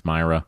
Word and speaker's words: Mira: Just go Twitter at Matt Myra, MattMira, spelled like Mira, pronounced Myra.